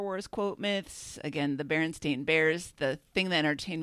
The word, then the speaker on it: Berenstain: Bravo, I'm pronouncing it correctly, by the way. The Berenstain Bears.